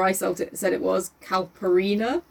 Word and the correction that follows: Calpurnia: It's Calpurnia.